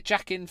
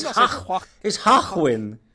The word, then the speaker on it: Joaquin: I want to say Jackin Phoenix. – It's Hachwin. But it's basically Tarquin, but someone with a speech impediment.